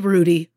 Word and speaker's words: Hrudey: I want you to know Kelly Rudy goes on the list. Rudy is spelled H-R-U-D-E-Y.